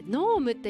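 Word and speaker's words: 濃霧: 霧の濃い霧と書いてノームって読むんですね。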